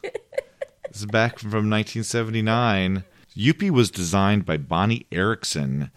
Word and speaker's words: Youppi: Y-O-U-P-P-I, Youppi.